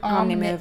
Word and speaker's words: Omnimover: This is a very similar ride to The Seas with Nemo and Friends, which is a dark Omnimover. Is that how you pronounce it? – Mm-hmm. Omnimover.